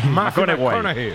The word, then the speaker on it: McConaughey: Hay que ir corrigiendo la pronunciación. – McConaughey. McConaughey.